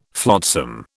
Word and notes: flotsam: British English

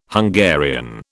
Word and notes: Hungarian: British English

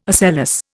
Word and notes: ocellus: Indian

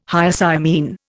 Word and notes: Hyoscyamine: Indian

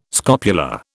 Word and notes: scopula: British English

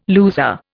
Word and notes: loser: Indian

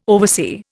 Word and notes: oversee: Indian

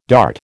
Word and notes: dart: American English

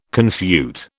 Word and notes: confute: British English

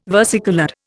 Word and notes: versicoloured: Indian

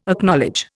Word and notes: Acknowledge: Indian

